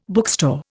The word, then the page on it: bookstore: Indian